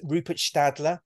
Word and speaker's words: Rupert Stadler: And of course, this is Dieselgate again, and Rupert Stadler.